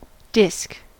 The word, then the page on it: disc: American English